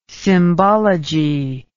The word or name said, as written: symbology